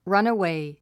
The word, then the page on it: runaway: rʌ́nəwèi; ラナウェイ